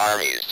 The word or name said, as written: armies